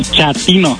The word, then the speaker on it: chatino: Chapino. – ¿Chapino? – No, chatino. – Es como chato, ¿verdad? Chatino. – Sí, azunero como chato, chatino.